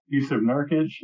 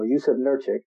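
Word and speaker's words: Jusuf Nurkić: Yusuf Nurkic. – There you go, Yusuf Nurkic.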